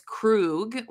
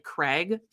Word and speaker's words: Krug: His name is Krug, which she we'll talk about this in a little bit but like she calls him like tries to pronounce his name and ends up like kind of saying it like craig so for the sake of the story i'm gonna call him craig okay?